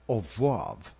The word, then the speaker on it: oeuvre: It's pronounce it again. – Oeuvre. It's a French word for entire body of work. – Oeuvre. Oeuvre? – Yeah. Good.